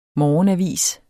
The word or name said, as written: morgenavis